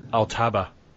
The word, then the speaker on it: Altaba: It'll be how do you pronounce it? – Altaba.